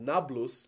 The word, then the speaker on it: Nablus: Wow, and that's why the Arabs today call it Nablus, because Arabs can't pronounce the P sound. Thus, Neapolis becomes Nablus.